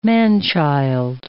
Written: man-child

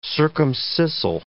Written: circumscissile

